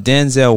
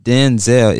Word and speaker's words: Denzel: It's not Denzel, it's Denzel. That's what his mother named him, originally named him. Denzel, not Denzel, Denzel.